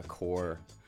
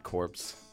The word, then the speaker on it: Corps: So it is pronounced core, not corpse. Mm. And I think we're going to spend the rest of our lives telling that. – To immigrants like myself who don't know how to spell or read. – Well, exactly. We're in the same boat, but we were born in Canada. So it's you know, it's fine. – Okay, collectively, now we know how to pronounce, so it's spelled C-O-R-P-S, but P-S is wasted. It's just on paper, not on the audio version of the title, The Core.